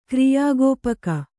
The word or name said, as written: ಕ್ರಿಯಾಗೋಪಕ